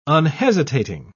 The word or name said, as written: unhesitating